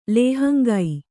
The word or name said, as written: ಲೇಹಂಗೈ